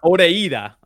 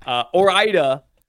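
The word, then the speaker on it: Ore-Ida: Because yeah, it's not Oreida. I know that. Orida, whatever, the French fry brand.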